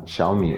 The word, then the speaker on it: Xiaomi: La vera pronuncia è Xiaomi o Xiaomi, perché tu l'hai pronunciato in maniera diversa da come l'ho pronunciato io. – E la pronuncia vera è Xiaomi. Xiaomi vuol dire piccolo chicco di riso, letteralmente.